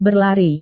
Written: berlari

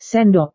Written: sendok

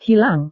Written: hilang

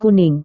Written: kuning

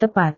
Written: tepat

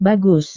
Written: bagus

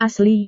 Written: asli

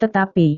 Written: tetapi

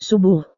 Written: subuh

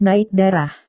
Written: naik darah

